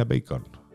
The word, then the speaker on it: Bacon: A mí, eh, Bacon, no, Bacon no, Bacon, ¿no? Se, pronuncia Bacon.